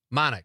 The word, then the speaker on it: Monick: Am I saying that right? Manick? Monick. I thought so. Rachel Monick. Rhymes with Bionic.